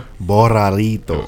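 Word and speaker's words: Barrilito: Roll your R again, yeah. – Barralito.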